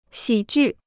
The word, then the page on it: 喜剧: xǐ jù